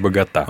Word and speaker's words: Богота: Ведь вы, наверное, сейчас правильно сказали. А мы всегда говорили Богота. – Богота. – Богота. – Правильно говорит Богота. – Ага. Вот будем знать.